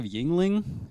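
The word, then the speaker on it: Yuengling: Judd, a lifetime supply of Yingling. Am I pronouncing that right? Because I've never had it. – Yeah, you got it right.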